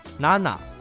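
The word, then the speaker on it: なな: 同理，七一般读作 nana，九一般读作 q。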